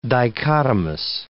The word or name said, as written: dichotomous